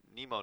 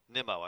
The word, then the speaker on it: Nemo: I was like, why did Nemo not make that play? Nemo, I should say. It's pronounced Nemo, according to him. Pronunciation guide says Nemo.